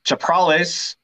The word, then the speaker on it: Chaprales: All right, we're back with episode 16 with Niko Chaprales.